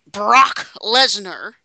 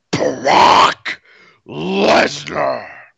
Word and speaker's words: Brock Lesnar: Depending on what we can arrange, if you New York people are not interested in seeing Brock Lesnar in action, perhaps our next WrestleMania will be razzing you from Philadelphia. – But before we go into that, you said it wrong. It's Brock Lesnar.